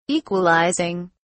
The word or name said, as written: equalizing